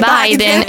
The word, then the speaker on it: Biden: Si dice proprio Biden. – Biden si dice, cioè Biden si dice Biden. – E Biden si dice Biden.